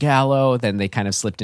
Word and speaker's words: giallo: Giallo.